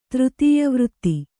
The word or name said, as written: ತೃತೀಯವೃತ್ತಿ